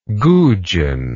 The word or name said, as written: goujon